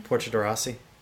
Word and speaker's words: Portia de Rossi: Portia de Rossi. – Portia de Rossi? – You're saying Grassi. Like Portia she's de Rossi. From Degrassi.